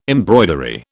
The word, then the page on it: embroidery: British English